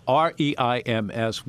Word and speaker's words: Reims: I love talking about it because the main headquarters of the Champagne region is a city called Reims, which is pronounced by French people as yeah, the clear your throat city.